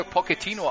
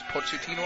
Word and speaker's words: Pochettino: der schreibt sich mit CCH und ich habe ihn immer Pochettino ausgesprochen. Dann hat mir irgendwann mal einer erklärt, der muss Pochettino ausgesprochen werden. Dann habe ich gefragt, warum? Und dann war die Antwort, weil der nicht aus Italien ist, sondern aus Argentinien. Und da macht man es anders.